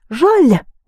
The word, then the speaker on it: жаль: So, you had this жал in пожалуйста, пожаловать, and жаль with a soft sign is pity. Жаль. How do you say, it's a great pity, which is just very pity? – Очень жаль. – Жаль, but pronounce it soft.